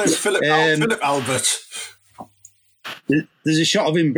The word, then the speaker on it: Albert: It is Albert. It's Albert. It's Belgium. It's pronounced Albert.